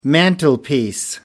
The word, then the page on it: mantelpiece: American English